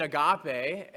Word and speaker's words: agape: You can spell it A-G-A-P-E, agape.